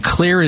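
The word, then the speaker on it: Xlear: So clearing out the sinuses with this all-natural, non-addictive, safe and effective nasal spray, and Clear is spelled with an X, X-L-E-A-R, Clear Rescue Nasal Spray.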